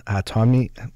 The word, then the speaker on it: Hatami: Raha is the daughter of Nana's Hatami. I'm saying it right. – Yeah, that's perfect.